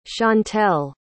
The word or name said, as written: shantell